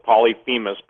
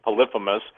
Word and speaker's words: Polyphemus: Some people pronounce it Polyphemus, but I believe it's Polyphemus.